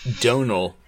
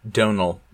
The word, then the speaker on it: Domhnall: And it looks like one of the things you wanted to give a heads up on was Domhnall Gleeson. Is that how you say his name? – Donal. – Donal. – It's Donal. Yeah, Donal the M Gleeson. Is silent, apparently.